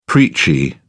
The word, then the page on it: preachy: British English